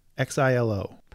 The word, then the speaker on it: Xilo: And I don't know how would you pronounce this? Xilo? Xilo? X I L O. – Probably Xilo.